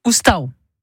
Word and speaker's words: oustau: La maison en provençal, ça se dit oustau. – C'est parfait. Merci.